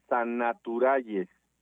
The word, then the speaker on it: Sannaturals: Sannaturals se escribe con doble N y doble L. Es Sannaturales.